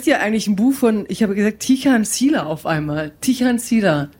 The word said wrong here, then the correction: Tijan: Tijan haben wir doch gesagt. Wir haben Tijan gesagt. – Tichan. – Entschuldigung, achso, Entschuldigung, wir müssen es aussprechen wie das deutsche Töreton.